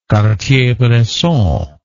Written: cartier bresson

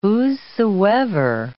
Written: whosesoever

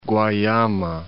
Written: Guayama

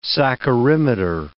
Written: saccharimeter

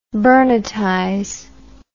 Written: burnettize

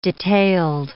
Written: detailed

